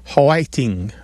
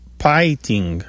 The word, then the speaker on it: hwaiting: It's often spelled H-W-A-I-T-I-N-G or P-A-I-T-I-N-G. And it sounds like three syllables instead of two. So it might be like whiting or piting, like that. – Yes. And sometimes it sounds like whiting, like with a W almost.